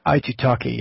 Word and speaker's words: Aitutaki: I've taken large GTs on both Christmas and, how do you pronounce it, Aitutaki? – Aitutaki, yeah.